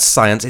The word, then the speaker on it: Psyence: So they're called Psyence. It's spelled P-S-Y-E-N-C-E.